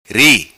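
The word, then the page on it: Ri: patua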